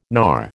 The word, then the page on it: knar: American English